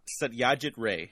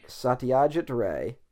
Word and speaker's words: Satyajit Ray: All right, remind me how to pronounce the name again. – Okay, let me see. – It's embarrassing. I'm terrible. I can't even pronounce, like, American names, so... – All right, ready? Satyajit Ray.